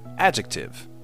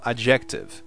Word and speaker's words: adjective: The next one is, a próxima é A-D-J-E-C-T-I-V-E, adjective, é adjetivo em inglês. E por ter essa pronúncia em português, adjetivo, muitos pensam que se fala adjective, mas não é assim, é adjective. And finally, e finalmente, B-E-A-C-H, beach, praia, e B-I-T-C-H, bitch, cadela.